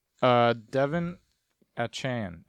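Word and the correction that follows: De'Von Achane: Achane? I don't know how to say his name. Versus Denver. – Devion Achane. – Sorry, Devion Achane.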